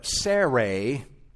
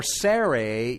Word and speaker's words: Sarai: Then God said to Abraham, and I'm going to, I haven't been doing this all of these months, but I'm going to now pronounce Sarah's name the way it actually is in the Hebrew. Um, as for Sarai, your wife. That's how her name has been pronounced uh, up to this point of Genesis, even though we've been saying Sarah for convenience sake.